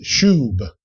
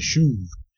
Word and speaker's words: shub: In the Old Testament, the word for repent is shub. Can you say that? Shub. In some dialects, the B is pronounced like a V, shuv. Either way, a Hebrew would hear that as repentance.